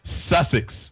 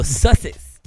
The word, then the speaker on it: Sussex: Sussex. – Oh, Sussex.